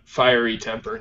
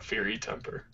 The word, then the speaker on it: Fiery Temper: Fiery Temper, not Fury Temper.